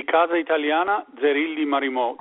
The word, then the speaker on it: Casa Italiana Zerilli Marimò: NYU's Casa Italia, how do you say it the right way? – It would be Casa Italiana Zerilli Marimò.